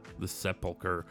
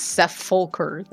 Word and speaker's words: Sepulchre: The Sepulchre. I never knew how to pronounce it. So it's definitely not Seth Fulker, though.